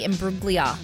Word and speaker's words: Imbruglia: And Natalie Imbruglia for Torn. – Yep. – Did I say that right, Scotty? – Okay. – That's one of Silent those. No G? – No G.